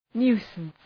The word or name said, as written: nuisance